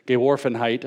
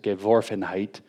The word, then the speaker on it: Geworfenheit: Heidegger, in his famous concept of the flungness of being or thrownness of being, depending on the translation of Geworfenheit, I guess I said that wrong, it's Geworfenheit, kind of characterizes what this is.